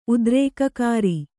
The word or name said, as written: ಉದ್ರೇಕಕಾರಿ